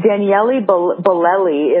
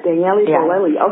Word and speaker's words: Daniele Bolelli: Okay, and that'd be last but not least, Sex Talk with historian Daniel Bolelli. Did I pronounce that right? – It's actually pronounced Daniele Bolelli if he's the Italian. – Ah, Daniele Bolelli.